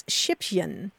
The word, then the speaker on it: Schippchen: Yes, and the Germans do have a term for this, and it's Schippchen. And you spell that S-C-H-I-P-P-C-H-E-N, Schippchen, and it means little shovel, which I think is so adorable.